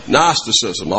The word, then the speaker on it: Gnosticism: Well, they were influenced by a false teaching that was called, it still is called, Gnosticism. I'll spell that for you. G-N-O-S-T-I-C-I-S-M. The G is silent.